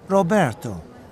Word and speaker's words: Roberto: C-A-M-A-C-H-O. – Thank you. And your first name? – Roberto. That's R-O-B-E-R-T-O.